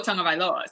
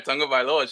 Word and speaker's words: Tagovailoa: Tua Tagovailoa. So it's like interesting. Exactly. When you do have to pronounce his entire name, I think that's where it went. – She just nailed it right there, Tagovailoa.